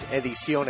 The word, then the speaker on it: Edición: Close. It was Edición Exclusiva.